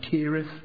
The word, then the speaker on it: Cherith: It's spelt Cherith. The correct pronunciation is actually Kirith.